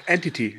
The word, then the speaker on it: Entity: Antiddy gedacht oder wird es Entity ausgesprochen, wo der Geist immer die Frau vergewaltigt und der nachstellt? Ach, Entity, ja.